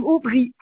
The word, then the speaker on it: Aubry: Oui, alors madame Aubry, A-U-B-R-Y, madame Aubry Mireille.